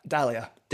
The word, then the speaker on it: Dahlia: And you did that with, was it Delia, did you say her name was? – Dahlia. – Dahlia, because I was going to say Delilah when I was reading it, and I was like, that's not Delilah.